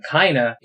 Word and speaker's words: Kaina: I was really confused when I saw that you pronounce his name Kaina, because it looks like it's pronounced mm-hmm. Kane. But mm-hmm. then I realized, oh, Kaina and Haiga.